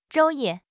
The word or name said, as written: 昼夜